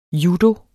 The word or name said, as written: judo